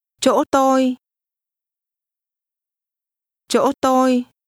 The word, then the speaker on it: chỗ: Chỗ tôi. Chỗ. Chỗ. – The word for place is pronounced with a tone which begins to rise, but is then sharply cut off.